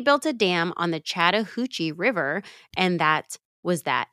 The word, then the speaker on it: Chattahoochee: They built a dam on the Chattahoochee River. – Chattahoochee. – That's it. You're saying it wrong. It's not Chattahoochee.